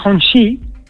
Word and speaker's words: Hongqi: Sí, se escribe Hongqi, pero se dice Hongqi, ¿no?